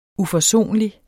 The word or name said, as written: UFORSONLIGT